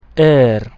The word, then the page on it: R: Latin